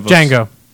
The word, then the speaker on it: Django: I wasn't a fan of Django. It's pronounced Django.